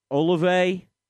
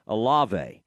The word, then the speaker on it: Olave: Olave. That's right. I knew that it wasn't sounding right in my head. Olave.